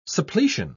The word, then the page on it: suppletion: British English